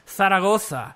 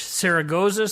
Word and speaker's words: Zaragoza: They're going, there was a flight to, name of the city in Spanish is pronounced Zaragoza. In English, Saragossa, some people say. The Brits changed the Z to an S, so there you go.